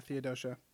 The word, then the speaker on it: Theodosia: Philip is born, and Burr also has a daughter, Theodosia. I think I'm saying that right. Yeah, Theodosia. And we get this awesome, like, kind of side by side song between the two of them.